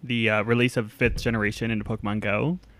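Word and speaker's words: Pokémon: My weekend geek is about three syllables. It is Pokemon.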